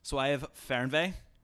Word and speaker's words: Fernweh: Does the German word Fernweh mean anything to you? – You say it like a native speaking German. I can tell you that. – French is my specialty, but it's Fernweh.